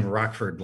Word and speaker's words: Rochford: I'm told you pronounce his name Rockford like the city. It's spelled R O C H F O R D.